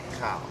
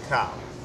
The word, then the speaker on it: Kyle: Kyle. It's one syllable.